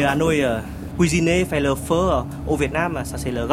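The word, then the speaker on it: phở: Et l'une des spécialités de sa maison, Hanoï 1988, c'est le feu. Ça s'écrit faux, P-H-O, mais ça se prononce feu.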